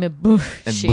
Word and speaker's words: Ibushi: I pronounce his name Ibushi. – Ibushi.